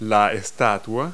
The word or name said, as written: statue